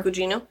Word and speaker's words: Gugino: Carla Gugino. I think I said that right. – Sure. – Gugino.